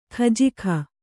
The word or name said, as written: ಖಜಾಕೆ